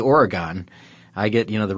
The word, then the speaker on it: Oregon: So in Oregon, for instance, or Oregon, which is apparently the way I'm supposed to be pronouncing this.